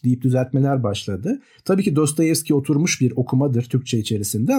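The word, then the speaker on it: Dostoyevski (Достоевский): Ee, yanlış öğretmedilerse, yanlış uyarmadılarsa beni esas olarak Rusça okunuşu Dostoyevski.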